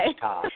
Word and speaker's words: Wichita: Wichita. Say wicked pissa. – Wicked pissa. Right? – All right. Now, one other thing that I want to bring up about that night in Wichita.